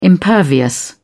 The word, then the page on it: impervious: British English